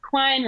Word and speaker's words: Quine: So I want to do a post that looks like an Art Deco like liquor poster, and it says Quine rhymes with wine, and I'll do like a wine glass and stuff like that.